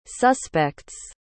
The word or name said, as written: suspects